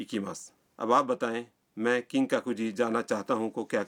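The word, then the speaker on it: کنگ کاکو جی (金閣寺): مثلاً آپ کیوتو کے کنگ کاکو جی مندر جانا چاہتے ہیں. مندر کے نام کا تلفظ ہے کنگ کاکو جی.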